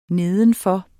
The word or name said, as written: nedenfor